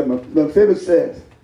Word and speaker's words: Mephibosheth: Yeah, Mephibosheth. I'm hillbilly, church. Praise the Lord. And I was saying that perfect at home. But he's going to eat at my table. And so, Mephibosheth being a cripple, every day they had to pick him up and carry him and set him down at the king's table.